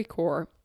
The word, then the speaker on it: Corps: and it is called the Story Corps, and it's spelled C-O-R-P-S Corp.